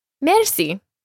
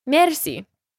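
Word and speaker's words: Merci (مرسی): Now, this might sound familiar to you because it is taken from the French word for thank you, merci, just pronounced slightly differently with the R rolled. Merci.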